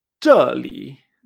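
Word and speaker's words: choli (这里): Entonces esto se pronuncia choli. La palabra clave que vamos a utilizar es aquí. Aquí en chino se dice choli la última es como si fueran dos tiros, dos cuartos tonos, pam, pam, ¿eh?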